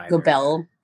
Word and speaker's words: Goebel: Goebel or Goebel? I don't know. – Goebel. Paris Goebel, anyway.